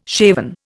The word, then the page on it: shaven: Indian